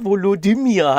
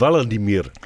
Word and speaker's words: Volodymyr: Volodymyr. Ja, daran so schlimm? – Weil es einfach Volodymyr heißt. Also die dritte Silbe ist einfach... – Es heißt eigentlich Volodymyr.